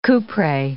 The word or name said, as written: kouprey